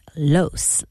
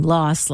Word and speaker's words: Los Angeles: Jamie Lee pronounces it Los, like rhymes with gross. Mariska is team Los, like boss.